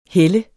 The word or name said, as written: helle